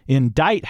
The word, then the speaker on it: indict: Indict, however, has a silent C, which seems insane to me.